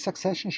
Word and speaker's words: Succession: Habe ich Succession schon mal gelobt? – Noch nie so gut ausgesprochen wie heute.